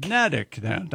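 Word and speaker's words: Neddick: It rhymes with medic, according to this. – Nedic. – Nedic, not Nedic. – It sits on the small Nubble Island. – What? – Nubble. N-U-B-B-L-E.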